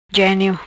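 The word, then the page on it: Genio: patua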